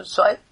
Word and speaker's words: Zweig: As Freud himself told the writer Stephen Zweig, okay, the pronunciation Zweig, artists know instinctively what the psychologist takes years to discover.